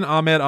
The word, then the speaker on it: Ahmed: I hope I'm pronouncing that correctly. Because it's A-H-M-E-D. That's Ahmed, right? – Surely.